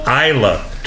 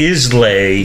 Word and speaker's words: Islay: Single malt Isla. – 12-year-old? – Which is spelled Islay. – Right, pronounced Isla.